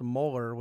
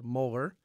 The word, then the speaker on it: Mueller: and no one has ever explained to me why it's pronounced Mueller when it is spelled Mueller. But it is, in fact, pronounced Mueller, and it is, in fact, spelled Mueller.